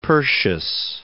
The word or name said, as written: Persius